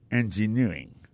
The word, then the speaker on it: engineering: Engineering, ça, l'accent tombé le même sur avant dernière syllabe là.